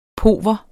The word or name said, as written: pauver